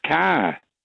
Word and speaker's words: Kerr: And the man, Josh Kerr, K-E-R-R, sometimes it's pronounced Carr, isn't it? I remember the film actress, Deborah Carr, K-E-R-R.